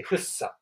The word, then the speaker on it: 福生: あの、幸福の服にあの生きるって書いてフッサって読むんですけど、総武線とかね、で、あ、総武線ん中央線か。